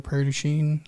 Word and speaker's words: Prairie du Chien: Like 2 weeks ago, we went to Prairie du Chien, I guess that's, if that's how you pronounce it. – Yeah, you did. You got it right.